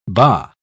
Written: bah

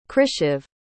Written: krishiv

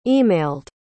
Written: emailed

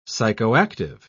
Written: psychoactive

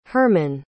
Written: Hermann

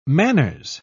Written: manners

